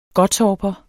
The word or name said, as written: gottorper